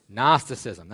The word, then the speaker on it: Gnosticism: Let me hear you say Gnosticism. Gnosticism, that's right. Gnosticism is spelled G-N-O-S-T. That's the beginning of it.